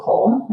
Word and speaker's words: Coll: You can search for Rachel Coll. My last name is spelled C-O-L-L on LinkedIn.